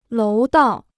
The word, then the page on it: 楼道: lóu dào